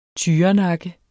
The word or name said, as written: tyrenakke